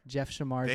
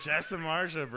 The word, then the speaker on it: Samardzija: Jeff Samardzija. – Thank you, dude. Jeff Samardzija, bro.